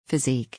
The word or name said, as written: physique